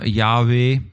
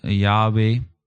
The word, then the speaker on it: Yahweh: En het zou waarschijnlijk worden uitgesproken als Yahweh. Jehovah waarschijnlijk niet, dat is de Engelse vertaling van Yahweh.